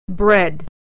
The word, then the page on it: bread: e